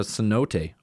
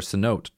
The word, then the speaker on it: cenote: And it was also built on top of a cenote or a cenote, depending on who you talk to. That's how you pronounced it, which is just a big, if you haven't been to Mexico, they call them a cenote or cenote.